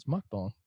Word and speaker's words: mukbang: It's mukbang.